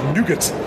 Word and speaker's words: Nougat: And, of course, as the Americans have discovered in the last couple of weeks, pronounce it Nougat.